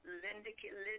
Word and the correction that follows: LinkedIn: I'm on there. – Oh, LinkedIn, yes, you're on LinkedIn.